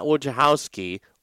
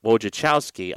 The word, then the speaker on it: Wojciechowski: Not Wojciechowski or Wojnarowski, but Wojciechowski.